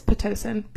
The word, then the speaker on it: Pitocin: C-I-N, I don't think that's right. Pitocin. – It's not pinto. – I said... It is pitocin. P-I... – You said pintos. – No, I said pitocin. Say it. Spell again. – P-I-T-O-C-I-N? – Mm-hmm.